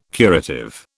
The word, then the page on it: curative: British English